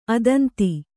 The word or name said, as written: ಅದಂತ